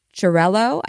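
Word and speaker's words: Xarel·lo: It's Xarello. Charello, I think is how you say it.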